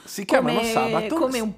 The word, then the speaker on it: Sabaton: che dubito che l'accento sia sull'ultima sillaba. – No, non è sull'ultima ecco. – sillaba, ecco. è sulla prima, è Sabaton.